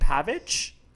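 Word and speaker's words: Pavić: Mektić and Pavic. – You got Pavic right.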